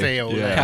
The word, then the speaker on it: Café au lait: I slightly preferred the Hot Benny's beignets, but the Café du Monde had Café a lot. Is that how you pronounce it? – Café au lait.